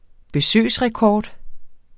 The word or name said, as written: besøgsrekord